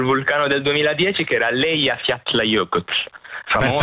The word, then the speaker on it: Eyjafjallajökull: Me lo sono meritato a provare addirittura a pronunciare, ripetilo per favore. – Eyjafjallajökull. – Perfetto.